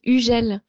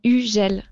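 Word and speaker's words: Hugel: Yeah, the pronunciation is Ugel. You don't say the H and the G is like J. So Ugel.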